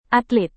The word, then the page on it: atlet: indonesian